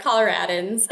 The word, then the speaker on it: Coloradans: Is it Coloradans? – You said it right. Coloradans.